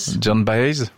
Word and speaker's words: Joan Baez: C'est Joan Baez? – John Baez, vous le prononcez mieux que moi.